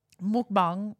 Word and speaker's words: mukbang: Anyway, while he's doing that, mukbang, right. Which is how it's pronounced, is it's a Korean thing. It started in Korea.